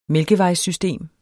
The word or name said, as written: mælkevejssystem